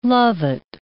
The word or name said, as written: lovat